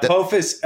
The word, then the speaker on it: Apophis: Apophis asteroid. Is that the right? Am I saying that right? – Yeah, Apophis. Apophis is the centrality of what's going on.